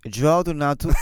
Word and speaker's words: João Donato: João Donato e Donatinho,